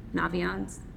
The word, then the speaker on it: Naviance: Are there also in Naviance? Am I finally pronouncing it correct? Okay, good.